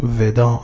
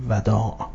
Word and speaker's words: وداع: واژه‌ای که ملاحظه می‌فرمایید کلمه ودا، که ممکن است ما این رو به شکل مصطلح خودمون گاه به اشتباه ودا تلفظ می‌کنیم، یعنی با کسر واو و ابتدا تلفظ می‌کنیم، صحیح هم با فتح هست و کلمه ودا.